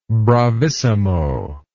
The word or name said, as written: bravissimo